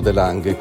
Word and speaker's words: de Lange: È stata scelta Tizia De Lange perché, o De Lange, come essendo olandese si dovrebbe pronunciare, perché ha chiarito uno dei passaggi fondamentali nella replicazione cellulare.